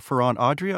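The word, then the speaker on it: Ferran Adrià: He was cooking a meal in honor of his hero, Ferran Adria.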